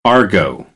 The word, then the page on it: argot: American English